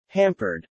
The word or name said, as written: hampered